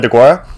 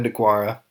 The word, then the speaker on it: Deguara: How do you say it? It's a Deguri. Is that how you say it? – Yeah. Decide Deguara? – Yeah. Okay. Yeah. Decide Deguara.